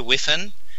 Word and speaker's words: Whiffin: My name is Peter Whiffin, which is spelled W, H for Harry, I for Igloo, F for Facebook, I for Igloo, N for Nelly.